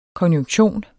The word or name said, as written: konjunktion